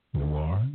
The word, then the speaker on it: noir: CODE NOIR. Am I right in that? – It's noir. You don't say noir like O-I-R. It's really okay. – Okay, that is a French term, CODE NOIR.